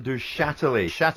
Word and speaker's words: Duchatelet: Actually, Al, stay there because we think we know someone who might be able to help you with the pronunciation of that name. – We're talking about the owner, Roland Duchatelet. Duchatelet.